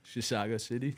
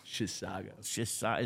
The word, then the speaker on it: Chisago City: My question to you is, you're from a town that I don't think I can pronounce. What's it called? – Chisago City. – Chisago. – Chisago.